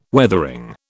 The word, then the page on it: Weathering: British English